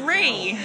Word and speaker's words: Rhi: My name's Ree. – Ree, how do I spell that? – R-H-I.